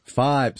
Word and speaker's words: Fybe: Am I saying this right? Fiber? – Five. – Sorry. – See, I believe. Yeah. Yes. F-Y-B-E. – F-Y-B-E. Five. Yeah, you tell me how to pronounce that.